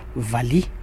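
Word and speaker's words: valiha: On prononce tout court Vali, phonétique V-A-L-I.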